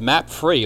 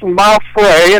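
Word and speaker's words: Mapfre: I think it's Maffrey, and it's an insurance company. Not to give them a plug, but I do believe that's how it's pronounced.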